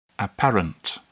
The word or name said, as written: apparent